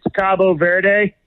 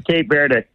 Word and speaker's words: Cabo Verde: They in Spanish, it's Cabo Verde. – Cabo Verde. – Cabo San Lucas or whatever in Mexico. Cabo Verde. But okay. – The English pronunciation is Cape Verde.